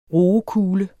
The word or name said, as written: roekule